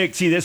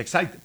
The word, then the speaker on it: Exide: Dentro se escribe Exide, se pronuncia Exide.